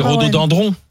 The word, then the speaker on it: rhododendron: Attends, deux secondes, parce qu'on dit Rhodo. – Dindron. – C'est piégeant. – C'est dendron. Alors c'est rhododendron.